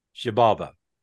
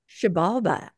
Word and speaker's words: Xibalba: Xibalba. – Xibalba.